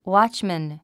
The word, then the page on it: watchman: ウォッチマン